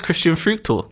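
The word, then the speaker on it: Christian Früchtl: How would I pronounce that name? – Oh, it's Christian Fruchtel.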